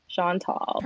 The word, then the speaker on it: Chantal: Chantal. Is that how you say it? – Yeah. – Yeah, like Sean is tall. Sean tall. Perfect.